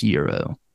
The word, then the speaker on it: gyro: It's spelled G-Y-R-O, so it's like gyro, but it's pronounced Euro.